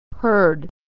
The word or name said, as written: heard